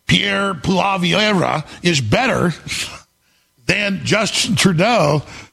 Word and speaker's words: Poilievre: So, Pierre Poilievre, I got that right.